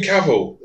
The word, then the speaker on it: Cavill: How'd you say his name? – The Witcher. – Cavill. – Is that how he says it? – It's Cavill. – Henry Cavill.